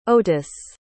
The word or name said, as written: otis